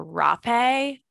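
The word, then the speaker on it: Rapé: It can be pronounced Hape, which is how I say it, with an accent over the E, or Rape, R-A-P-E, apostrophe over the E.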